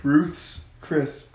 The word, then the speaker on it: Ruth's Chris: Ruth's Chris. – Well, if you put a stutter in it, then yeah. – What do you mean a stutter? That's how you pronounce it.